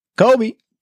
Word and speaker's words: Kobe: You didn't say it right. Try again. – Sorry, Kobe. Wait. Kobe 5 ProTro Final Championship. – No, I meant just the first word. – What? – Kobe. Just like that. That's how you have to say it.